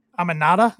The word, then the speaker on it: Aminata: We saw Akira Shida beat Queen Aminata, I believe is how you pronounce her name, which she is, mm-hmm.